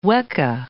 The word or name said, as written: weka